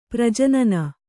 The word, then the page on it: ಪ್ರಜನನ: prajanana